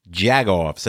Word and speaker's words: Jagoff: And, I had to look that up because in Jersey, we kind of use that word, but we say jack-off. We spell it with a C-K. You guys spell it with a G. – Correct. – J-A-G. Jagoff.